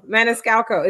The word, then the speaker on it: Maniscalco: Maniscalco. Is that how you say it? – There, that might, hey, you did it best, I think.